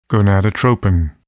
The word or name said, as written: gonadotropin